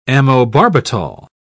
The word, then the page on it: amobarbital: British English